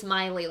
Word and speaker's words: Molloy: Okay, I always kept saying Molly. It's Miley, like Miley Cyrus.